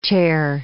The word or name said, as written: chare